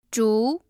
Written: zhu2

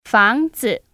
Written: Fangzi

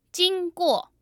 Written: jingguo

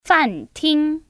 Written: FanTing

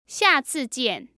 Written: xiacijian

